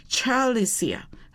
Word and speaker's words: Celisia: 因为他是苏格兰，所以那个 C 那个字应该是念成 Chal， Chalicia， C-E-L-I-S-I-A， Chalicia。